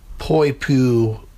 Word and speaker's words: Poipu: at the Grand Hyatt Poipu Resort.